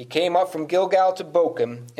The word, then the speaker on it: Bochim: So it's not Bochim. I did look it up. Like, it's not a CH as in church, but a CH as in character. So it's Bochim.